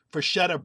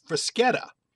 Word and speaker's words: Freschetta: They call themselves Freschetta, F-R-E-S-C-H-E-T-T-A, Freschetta Brick Oven Real Italian Pizza. However, what we just were talking about with bruschetta, the S-C-H should be pronounced Freschetta.